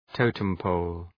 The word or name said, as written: totem pole